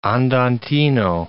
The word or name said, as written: andantino